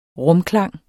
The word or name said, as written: rumklang